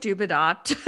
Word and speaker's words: Dubedat: We're going with Dubedat. It's spelled D-U-B-E-D-A-T.